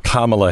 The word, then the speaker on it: Kamala: But I mean, if you listen to her say it, is Kamala. – Kamala Harris.